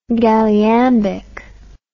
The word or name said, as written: galliambic